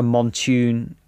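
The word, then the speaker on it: Mountune: And it's actually got the previous owner has a Montoon, is that how you pronounce it, exhaust on it? – Yeah, I think so. I think that's correct, yeah.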